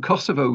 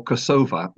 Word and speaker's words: Kosovo: Yes, when Kosovo was becoming independent of Serbia, did you call it Kosovo, which is actually the Serbian pronunciation of the name, or Kosova with an A at the end and stressed differently, which is the Albanian pronunciation?